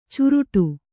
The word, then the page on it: ಚುರುಟು: curuṭu